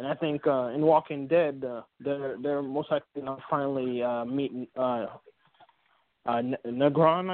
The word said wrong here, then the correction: Negan: I think is how they pronounce the guy. He's like a gang. Negan, right? Thank you.